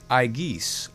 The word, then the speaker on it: aigis: AI is pronounced like the long I in high or fight. So in Greek, aigis, our word aegis, symbol of authority, aigis.